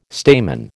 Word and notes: stamen: American English

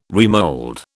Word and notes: remold: British English

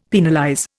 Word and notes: penalize: Indian

